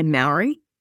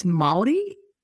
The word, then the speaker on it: Māori: So I guess, like, if you're not from New Zealand, a lot of people say Maori. – Yeah. – But it's Māori.